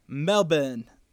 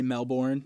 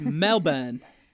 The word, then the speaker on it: Melbourne: And then she put in parentheses, pronounced Melbourne in Aussie, by the way. – I think I said that wrong on like an old part of Taylor Talk. – Did you say Melbourne? – Yeah, because I didn't know. – It's Melbourne.